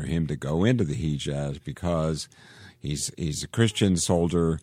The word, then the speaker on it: Hejaz: I did go back and make sure I could pronounce Hejaz right, and that's how you say it. So it is H-E-J-A-Z.